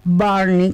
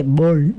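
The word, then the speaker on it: Burnie: Barney, que se dice Burney. Ajá, pero sabemos que se escribe Burney, ¿ok?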